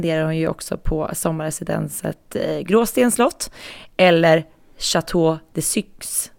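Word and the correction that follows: Château de Caix: Så jag rätt nu, Jenny? – Nej, Chateau de Caix.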